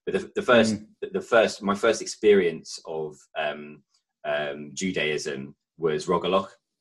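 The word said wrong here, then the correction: rugelach: Is that am I saying it right? Rogaloch? – The cake. – Yeah, the chocolate. Rugula.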